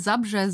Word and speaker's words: Zabrze: April 1933 in Hindenburg in Oberschlesien geboren, eine Stadt, die im heutigen Polen liegt, und Dzabrze oder Zabrze? – Ich würde Zabrze sagen. – Zabrze. Ja, ich dachte, wenn es einer aussprechen kann, dann wahrscheinlich eher du als ich, also Zabrze.